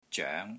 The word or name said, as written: ZOENG2